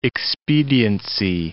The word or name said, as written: expediency